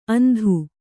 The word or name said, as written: ಅಂದು